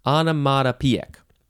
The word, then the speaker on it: onomatopoeic: Onomatopoeic. I think I added a syllable. Onomatopoeic. There we go. O-N-O-M-A-T-O-P-O-E-I-C.